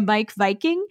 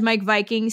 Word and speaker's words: Meik Wiking: Sorry, Danish Secrets to Happy Living by Mike Viking, which you would think you know how Mike Viking is spelled, but it is in fact M-E-I-K-W-I-K-I-N-G.